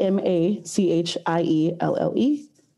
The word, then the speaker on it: Machielle: I am most available on Twitter, um, just at Machielle Thomas. My name is spelled a little more complicated than most, so it's uh, at M A C H I E L L E T H O M A S at Machielle Thomas on Twitter.